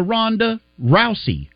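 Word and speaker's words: Ronda Rousey: Big shout out to Rhonda Rousey. Am I saying it right? – That is correct.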